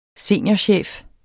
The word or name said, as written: seniorchef